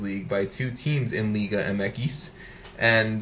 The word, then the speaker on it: Liga MX: Is it Liga MX or is it Liga Mekis? – It's Mekis. I'm American and I'll butcher. It's because Spanish X is Equis. So, I got you. – So I butcher it and I say Liga MX, but whatever. – Because I hear Daryl Groves say Liga Mekis all the time. – Yeah, it's Liga. It's technically Liga Mekis.